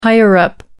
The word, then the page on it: higher-up: American English